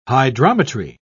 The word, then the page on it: hydrometry: British English